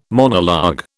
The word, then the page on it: monologue: British English